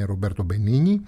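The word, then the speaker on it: Roberto Benigni: Έκανα λίγο πριν ένα λάθος, είναι Ρομπέρτο Μπενίνη, είπα ανάποδα τα δύο νι όπως πρέπει να να προφέρονται στην ιταλική γλώσσα.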